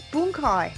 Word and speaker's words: bunkai: Patrick told you it's bunkai. – It's bunkai. I'm sorry.